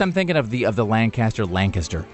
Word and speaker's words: Lancaster: I'm from Lancaster, which there is no G. It's Lancaster. But people who are from Lancaster prefer pronounce it Lancaster as if there is a G in there.